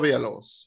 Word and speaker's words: Villalobos: Bernardo Villalobos. You have to roll your R's.